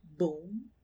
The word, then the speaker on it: Baum: Frank Baum. Is that how you pronounce his surname? – Mm-hmm.